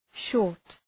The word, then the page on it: short: ʃɔ:rt